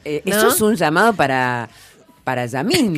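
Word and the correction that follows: Lamine: Claro. – ¿Lo dije bien? – Sí. – No. – Lamin.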